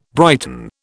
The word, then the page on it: brighten: British English